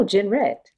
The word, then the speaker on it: Jenrette: No, Jenrette. Jenrette.